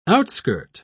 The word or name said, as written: outskirt